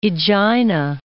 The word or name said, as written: Aegina